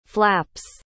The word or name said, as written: flaps